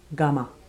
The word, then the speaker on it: gumma: Gumma. Plumber rhymes with gumma.